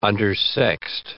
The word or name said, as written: undersexed